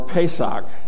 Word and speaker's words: Pesach: The first party is called Passover, or Pesach is how they pronounce it, Feast of Unleavened Bread.